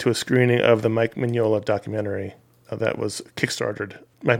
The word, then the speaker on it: Mignola: I didn't know anything about Mike Mignola, really. Also, that's how you pronounce his name.